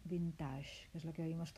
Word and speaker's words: vintage: Así que el que le haga ilusión pronunciar bien, que repita conmigo. Vintage, vintage. Es una especie de B, luego T y luego G, como G de James.